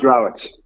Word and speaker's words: Rowett: Paul, is it pronounced Rowett or Rowett? – It's pronounced Rowett.